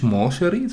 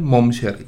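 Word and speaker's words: Mon Chérie: Das heißt nicht Moncherie, sondern Momcherie. Steht so auf der Karte. Habe ich richtig Oh, genau, das ist richtig ausgesprochen.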